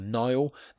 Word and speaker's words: Nihil: Nihil. That is spelled N-I-H-I-L. If any of you are familiar with the term nihilist, that's where it comes from.